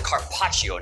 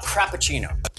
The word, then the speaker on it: carpaccio: Cindy, it's pronounced carpaccio, not crappuccino.